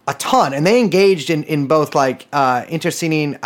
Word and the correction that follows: internecine: I do not think I pronounced that correctly. The kind of warfare where you kill your friends too. Internecine. Damn, first time saying that and not reading.